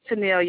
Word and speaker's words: Tenille: My name is Tenille Young. – Okay, could you spell that for me? – T-E-N-I-L-L-E Young.